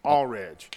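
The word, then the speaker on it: Alldredge: What's how you say his name? Allridge. – Yeah.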